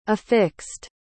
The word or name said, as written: affixed